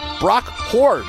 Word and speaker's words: Huard: You'll get to enjoy this game with the sweet sounds of Chris Myers, Brock Ward. Brock Huard, Brock Huard, and Jennifer Hale.